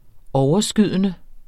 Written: overskydende

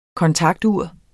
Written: kontaktur

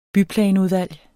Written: byplanudvalg

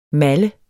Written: malle

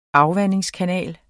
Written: AFVANDINGSKANAL

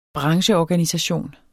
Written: brancheorganisation

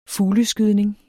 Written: fugleskydning

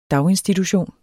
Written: daginstitution

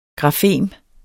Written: grafem

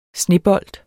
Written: snebold

